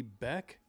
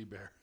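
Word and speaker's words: Boek: Yeah, we've done a lot of shows under the auspices of Lee Beck. Mm. Apologies to Lee if I'm mispronouncing his last name. It's B-O-E-K. Oh. But not Lee Bear.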